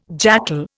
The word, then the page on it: jackal: Indian